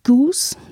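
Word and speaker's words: Geuss: Und eine letzte schöne und kurze Zusammenfassung zu den fließenden Grenzen zwischen Öffentlichkeit und Privatheit ist von Raymond Goose, nehme ich an, spricht man ihn aus, Goyce geschrieben. Privatheit, eine Genealogie.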